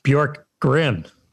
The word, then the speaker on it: Bjorkgren: But Nate Bjorkson, I think I'm pronouncing that right. – Bjorkgren. – Bjorkgren. Oh, yeah. Oh, it's Nate the Bjorkgren. S that's silent. How dare me? How dare I? – Bjorkgren.